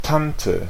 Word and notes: tante: France; [tɑ̃t]

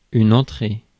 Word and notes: entrée: /ɑ̃.tʁe/

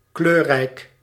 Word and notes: multicolore: /myl.ti.kɔ.lɔʁ/; France

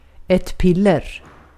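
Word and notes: piller: Swedish

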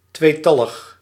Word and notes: tweetallig: [ˈtʋeː.ˌtɑ.ləx]

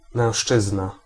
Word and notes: mężczyzna: [mɛ̃ˈʂt͡ʂɨz̪n̪a]; /mɛ̃w̃ʃˈʧ̑ɨzna/

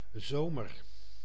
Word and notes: zomer: /ˈzoːmər/

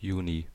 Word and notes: kesäkuu: /ˈke.sæˌkuː/

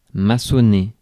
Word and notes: build: /bɪld/

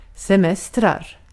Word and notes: semester: /sɛˈmɛstɛr/

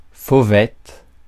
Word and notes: fauvette: /fo.vɛt/; France

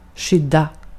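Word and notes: skydda: /ˈɧʏdˌa/; Swedish

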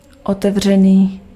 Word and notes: open: /ˈoʊ.pən/

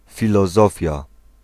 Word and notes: philosophie: [fi.lɔ.zɔ.fi]

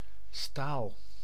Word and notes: staal: [staːl]; /sta:l/

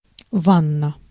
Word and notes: bath: /bæθ/; /bɑːθ/

